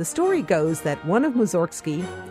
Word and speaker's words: Mussorgsky: In Russian, his name is pronounced Mussorgsky, which sounds like it has something to do with cows, but it doesn't.